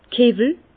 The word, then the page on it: d' Kiwwel: Bas Rhin; Herrlisheim